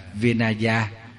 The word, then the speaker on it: Vinaya: Còn luật thì tiếng Phạn đọc là Vinaya.